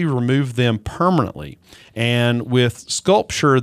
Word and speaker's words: SculpSure: And what I would say the best alternative to cool sculpting is called Sculpture. Now, it's not spelled like a work of art. It's spelled S-C-U-L-P-S-U-R-E.